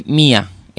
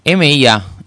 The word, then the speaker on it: M.I.A.: Bueno, estamos escuchando Mi, se pronuncia, se lee Mia, M-I-A, y se dice M-I-A.